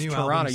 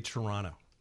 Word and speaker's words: Toronto: But it's Toronto. You don't pronounce the T. – No, you say Toronto.